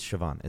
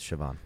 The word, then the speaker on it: Siobhan: I always forget how to pronounce that name, but that's like a it's like Siobhan something like that. – Yeah, it's Siobhan. It's Siobhan.